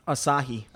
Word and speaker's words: Asahi: Asahi.